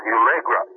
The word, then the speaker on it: Ulegra: Their main name for the energies they used was Ulegra.